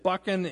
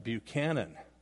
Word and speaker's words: Buchan: And so I heard about this president, Buchanan. And so I started pronouncing my name, Buchan, in the North.